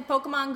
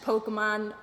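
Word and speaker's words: Pokémon: Yeah, because isn't there, like, an accent over the E? – Yeah, I don't know. I can't say it right. – Like, so wouldn't it be Pokemon? – Pokemon. – Gotta catch them all. – Pokemon. – Now, Pokemon. – There's no A in there.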